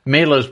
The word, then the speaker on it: Mahler: And Gustav Mahler, I think it's pronounced by our lovely and wonderful Denise said last night when I said, oh, it's Mahler's birthday. It's Mahler. Yes.